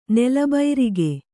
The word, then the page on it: ನೆಲಬೈರಿಗೆ: nela bairige